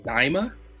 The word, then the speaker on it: Daima: For those of you who haven't seen, new series called Dragon Ball, I believe it's pronounced Daima. D-A-I-M-A.